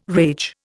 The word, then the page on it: rage: Indian